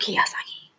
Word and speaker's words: Kiyosaki: Did I say his name right? Yes, I did. Robert Kiyosaki.